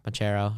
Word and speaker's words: Banchero: Paolo Banquero, Banchero, however you want to pronounce Banchero.